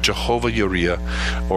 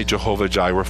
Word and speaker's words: Jehovah Jireh: And this whole word of Jehovah Yireh or Jehovah Jireh, I'm going to say Jehovah Jireh for the sake of how many times we've heard it pronounced that way.